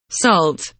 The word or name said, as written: salt